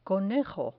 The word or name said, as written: conejo